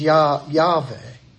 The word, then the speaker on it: Yahweh: The second word, the Lord, the pronunciation there is Yahweh.